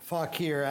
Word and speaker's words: Fauquier: Fauquier.